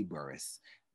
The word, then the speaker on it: Burris: Burris. Burris is spelled B-U-R-R-I-S.